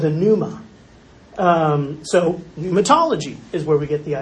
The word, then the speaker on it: pneumatology: This is called pneumatology. The P is silent.